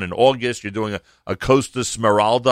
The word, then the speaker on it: Costa Smeralda: The Smeralda, which we're doing three times this summer, and you did pronounce it correctly.